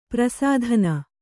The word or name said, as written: ಪ್ರಸಾಧನಿ